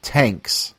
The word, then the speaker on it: Tanx: So let's move along to 1973's album Tanks. – Tanks. – Tanks. Mm-hmm. T A N X. Pronounced.